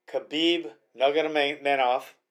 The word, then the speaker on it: Khabib Nurmagomedov: Conor McGregor, Khabib Nurmagomedov, and I know I said that right.